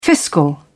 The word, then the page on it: fiscal: British English